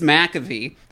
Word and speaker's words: McAvoy: So, the chemistry between James McAvoy, that's how you pronounce that.